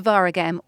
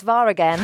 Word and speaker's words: Waregem: Let's discuss Sedo Berahino, as he's proving the doubters wrong, isn't he, by scoring goals again at his new club, Zolta Waregem. – There's a stop in play, it's Waregem.